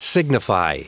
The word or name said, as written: signify